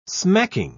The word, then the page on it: smacking: British English